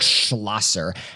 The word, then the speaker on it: Schlosser: My guy, Eric Schlossinger, he's got kind of I should probably say his name correctly. Eric Schlosser has kind of an interesting background and upbringing.